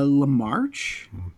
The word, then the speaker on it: Lamarche: Uh, is it Maurice Lur? How is it pronounced? Egon's voice there. Uh, Lamarche.